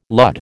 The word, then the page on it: lud: American English